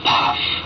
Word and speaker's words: Pfaff: P-F-A-F-F spells Pfaff, pronounced in Thai, Paff.